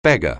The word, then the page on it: beggar: anglais